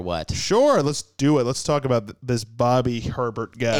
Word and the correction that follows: Hebert: Hebert, give it the French pronunciation. Hebert.